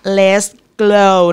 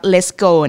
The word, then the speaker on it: Let's Glow: ออกเสียงตามคุณแม่นะคะ Let's Glow not let's go